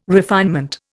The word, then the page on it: refinement: Indian